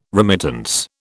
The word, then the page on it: remittance: British English